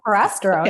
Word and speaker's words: Prasterone: And she prescribed me some, and you can tell me how, if I'm mispronouncing it, but Prasterone. – You got it. Prasterone.